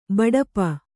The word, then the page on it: ಬಡಪ: kannada